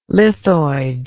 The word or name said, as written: lithoid